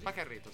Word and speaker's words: Pajarritos: I Pacarito, si pronuncia così? – Pacarito, sì.